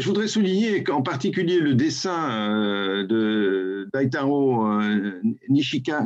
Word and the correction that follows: Nishihara: Nishihara, pas, pas, facile à prononcer en plus les noms japonais.